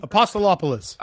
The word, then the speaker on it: Apostolopoulos: I also had, I'm going to butcher this name, I apologize, Steve Apostopoulos. Do I have his name? – Apostolopoulos. – I was close enough. I'll take that.